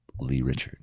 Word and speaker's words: Lerichard: They go Lerichard. – Lerichard, yeah. – I go, well, actually, it's Lerichard.